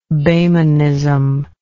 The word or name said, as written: Boehmenism